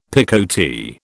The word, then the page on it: picotee: British English